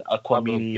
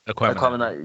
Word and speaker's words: Aquemini: People are gonna look at this shit the same way we're looking at like fucking ATLiens aliens right now and Aquamini or whatever from the OutKast. Aquemini.